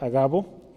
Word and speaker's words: Agabo: Un hombre llamado Agabo o Agabo, Agabo creo que es ahí el nombre, porque no hay acento en la A. Entonces es Agabo.